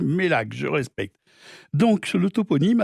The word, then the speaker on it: Meilhac: Alors, c'est assez bizarre, hein, parce que on revient sur la prononciation encore de Mélac, mais ça s'écrit pas pareil. Ça s'écrit M-E-I-L-H-A-C dans ce cas-là. – J'aurais dit Méliac, hein. – Ben oui, mais eux disent Mélac.